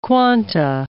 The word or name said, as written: quanta